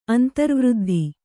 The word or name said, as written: ಅಂತ್ರವೃದ್ಧಿ